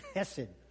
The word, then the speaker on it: Hesed: Remember when we talked about Hesed, and we tried to say it like Jewish people do with a little bit of chutzpah? So you got to get the in there. So Hesed, you go Hesed.